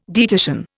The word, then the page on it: dietitian: Indian